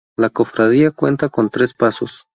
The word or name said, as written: pasos